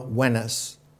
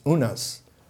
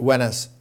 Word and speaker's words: Unas: And that reference wave was the pyramid built by the pharaoh Wenes, or sometimes it used to be pronounced and written as Unas, but Wenes is correct.